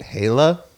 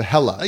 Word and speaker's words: Haelah: You, from the Bay Area, you named a band that and you pronounce it Hala? – As opposed to Hella. – Yeah. How do you not? – You're picturing it. It was spelled H-A-E-L-A-H.